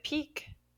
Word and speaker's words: Piqué: Question number 2, Piqué, in English we pronounce it peak, or we might spell it P-E-A-K of mountains.